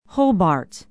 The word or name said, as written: hobart